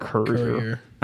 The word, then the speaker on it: Courier: Courier.